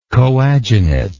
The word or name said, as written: coadunative